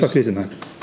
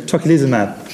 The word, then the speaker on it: tocilizumab: Tocilizumab. – Say again? – Tocilizumab. – We're going to get this tocilizumab.